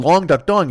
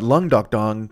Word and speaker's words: Long Duck Dong: Long Duck Dong, Long Duck Dong, Long Duck Dong. But Lung Duck Dong, because I mean, oh, I said it right, I think, that time. But if you say Lung Duck Dong, I did it wrong there.